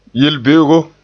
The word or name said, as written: yel-be'ego